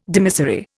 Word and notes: dimissory: Indian

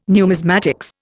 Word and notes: numismatics: Indian

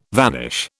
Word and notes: vanish: British English

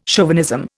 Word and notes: chauvinism: Indian

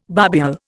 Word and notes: barbule: Indian

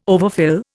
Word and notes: overfill: Indian

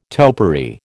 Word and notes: topiary: American English

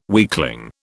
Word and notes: weakling: British English

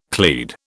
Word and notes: clade: British English

